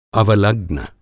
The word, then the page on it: अवलग्र: Hindi